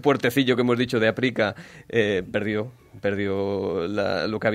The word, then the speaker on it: Aprica: Y estaba, pues, en la meta, en Aprica, que me di cuenta que allí no lo pronuncian Aprica, que es lo típico que se pronuncia aquí, sino Aprica, es decir, es llana.